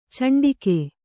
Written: ಚಂಡಿಗೆ

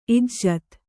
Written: ಇಜ್ಜತೆ

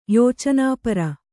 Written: ಯೋಚನಾಪರ

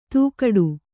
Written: ತೂಗಾಡು

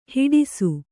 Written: ಹೆಡಸು

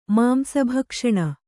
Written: ಮಾಂಸಭಕ್ಷಣ

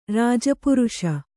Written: ರಾಜಪುರುಷ

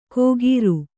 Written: ಖೋಗೀರು

